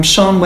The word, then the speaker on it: Shân: I'm on both LinkedIn and Twitter, and I'm Shân Wareing, and Shân is a Welsh name, but it's spelled with an H, S-H-A-N.